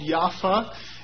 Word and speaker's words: Yaffa: In Arabic, it is called Yaffa. In Hebrew, Yafo. And in English, generally people butcher the Arabic and call it Jaffa.